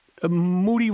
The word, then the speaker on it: Moody: Moody rhymes with duty.